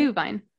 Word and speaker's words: Glühwein: Did I say it right? – Glühwein. Mm-hmm. Yeah.